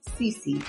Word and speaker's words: Sisi: You can also find me on SoundCloud under the same name, Ms. Cece, the real infopreneur. And Cece is spelled S-I-S-I, by the way.